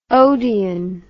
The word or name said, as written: odeon